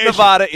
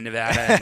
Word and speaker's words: Nevada: Not Nevada, it's Nevada.